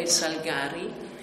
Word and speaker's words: Salgari: Allora, la pronuncia giusta è Salgari, perché essendo veneto, la famiglia originaria di Negrar Valpolicella, la pronuncia è, cioè, è Salgari e deriva dal vocabolo salice, che in veneto si chiama Salgari. Quindi non c'è dubbio che non è Salgari, ma è Salgari.